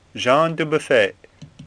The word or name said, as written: Dubuffet